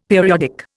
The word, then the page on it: periodic: Indian